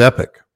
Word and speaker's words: Epoch: And I found this on Epoch News, E-P-O-C-H News. – Epic Times. – Is that how you pronounce it? Is it Epoch or Epic? – It's Epic.